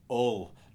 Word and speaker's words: Hull: It's one vowel away from hell, Hull. Nobody pronounces the H, Hull. Nobody says Hull. That, Hull. ULL.